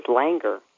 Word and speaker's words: Langer: My last name, when I was a girl growing up, was Langer. That's like hanger, only with an L.